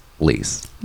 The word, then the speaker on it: Liis: L-I-I-S, pronounced Lies, I think. – Lies.